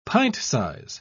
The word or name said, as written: pint-size